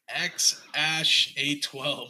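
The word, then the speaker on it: X Æ A-12: So it's spelled X, and the AE is pronounced as Ash, and then the A12, bro. So it's like... – So he's X-Ash, X-Ash. – It's X-Ash A12.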